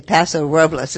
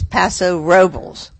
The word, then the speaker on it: Paso Robles: Locals call it Paso Robles. And a lot of people want to say Paso Robles because that's the Spanish pronunciation. – It sounds more Spanish. – Yes. And it is, quite frankly. But the locals, it's Paso Robles.